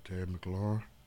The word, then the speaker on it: Terry McLaurin: Terry McLaurin.